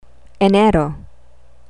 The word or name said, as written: enero